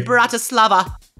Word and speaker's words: Bratislava: Well, you enjoy the rest of your night there in Bratislava. – Did I shall. Say that right? Okay. Yes, you did.